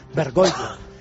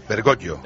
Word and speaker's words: Bergoglio: Los italianos lo pronuncian como nosotros, los que no somos yaístas o somos yaístas, Bergoglio. – Bergoglio. – Como si fuera una L. Es decir, no Gli. – Jorge Mario Bergoglio. – Como si fuera una doble L.